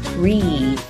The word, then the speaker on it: wreath: 注意开头的字母 W 不需要发音，直接从字母 R 开始发音就可以了。结尾的 TH 别忘了轻轻地咬一下舌尖哦。 Wreath.